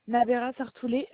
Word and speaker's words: Nabera Sartoulet: Nabera Sartoulet, Justine, mon prénom.